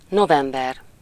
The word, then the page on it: November: [noʊˈvɛm.bɚ]; /nəʊˈvɛm.bə/